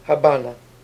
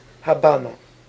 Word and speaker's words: Habana: Habana. – Is it? – Habana. – Why? – Habana. – We don't say V's like V's.